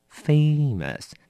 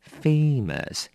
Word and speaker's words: famous: 第一个提醒你，小心它的发音念成 famous，你要拉长哦。 A famous，来，第一个我们常常用在一个片语里面叫 be famous for。